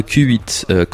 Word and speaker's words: Qhuit: So Q8, Q-H-U-I-T, is a brand of streetwear. And if you pronounce it Q8, it means when you get smashed, when you get trashed, when you get pissed in the British sense, when you just drink way too much.